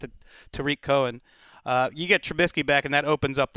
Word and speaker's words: Trubisky: And on top of it all, Mitch Trubisky, and not a moment too soon, Wes, is going to be back on the field for the Bears.